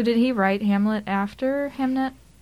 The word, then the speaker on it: Hamnet: The name Hamnet, which is the title of the book, I guess was interchangeable historically with Hamlet.